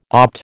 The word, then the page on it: opt: American English